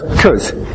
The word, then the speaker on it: because: pronounced as a monosyllable. In fact, as a reduced monosyllable, just 'cause.